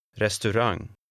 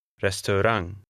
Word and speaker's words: restaurang: Restaurang. Rang. Trang. Restaurang.